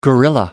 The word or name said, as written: gorilla